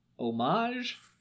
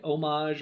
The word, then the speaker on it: Homage: It's called Homage. It's spelled like homage or homage. But with the H left out.